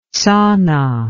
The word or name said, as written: tsana